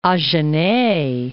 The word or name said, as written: Agenais